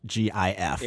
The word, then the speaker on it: GIF: I say it's GIF because the G stands for graphic. – Okay. – Now, that seems like the argument's over, right? – Right. – But apparently the gentleman who created the GIF pronounces it GIF.